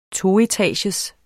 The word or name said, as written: toetagers